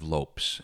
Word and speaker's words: Lopes: And I will always be profoundly impressed by a guy named Steve Lopes, L-O-P-E-S.